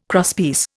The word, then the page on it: crosspiece: Indian